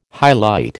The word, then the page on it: hyalite: American English